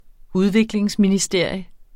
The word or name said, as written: udviklingsministerium